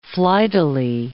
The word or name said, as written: flightily